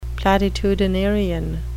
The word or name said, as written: platitudinarian